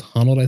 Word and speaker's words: Honnold: Honnold, I think is Honnold, how you say it.